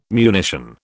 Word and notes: munition: British English

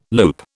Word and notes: loop: British English

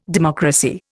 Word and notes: democracy: Indian

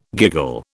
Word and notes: giggle: British English

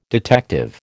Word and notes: detective: American English

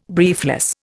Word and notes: briefless: Indian

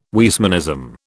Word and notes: Weismannism: British English

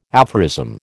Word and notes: aphorism: American English

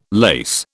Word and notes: lace: British English

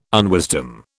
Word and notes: unwisdom: British English

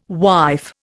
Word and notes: wife: Indian

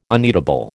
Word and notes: uneatable: American English

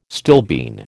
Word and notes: stilbene: American English

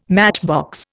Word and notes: matchbox: Indian

